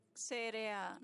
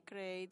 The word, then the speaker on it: CRA: Primero, el reporte es el reporte CRA, no el reporte CREI, dice él. Hay que pronunciarlo bien para no confundirnos.